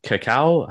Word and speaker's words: cacao: Cacao? How do you say cacao. – That? – Cacao. Yeah, cacao.